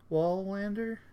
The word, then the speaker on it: Wallander: There's also a show called Young Wallander. Wallander? – I think you pronounced it correctly.